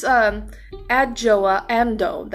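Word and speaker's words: Adjoa Andoh: So, I don't know how to feel about, I don't know how I feel about it, because she, this, Adjoa Amdo, that's how you pronounce her name, I actually had to go ahead and, pronounce her name.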